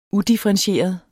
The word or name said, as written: udifferentieret